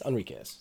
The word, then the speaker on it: Enriquez: As played by Warwick Davis, but as voiced by Daryl Enrique. – Well, thank you for clarifying. – Or Enriquez? Enriquez, sorry. It's spelled Enrique, but then it's apparently spelled differently here.